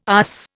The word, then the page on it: USS: Indian